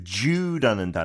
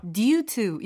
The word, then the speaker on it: due: 아, 그냥 참고로 그 Jew라는 단어 D-U-E. – Mm-hmm. – Yeah, in Britain we usually pronounce that D-U as a J almost.